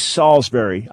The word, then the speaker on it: Salisbury: It is Salisbury. I mispronounced it the first time around. I get to redeem myself.